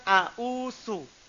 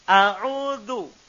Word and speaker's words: أعوذ: Perhatikan itu bukan a'usu. A'udhu. Za. Jangan salah.